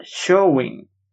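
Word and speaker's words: Samhain: Ellos servían y idolatraban a Showin. la pronunciación original de Shamhain.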